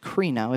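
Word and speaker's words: krino (κρίνω): Now, the word that he uses here is pronounced krino. It's a Greek word.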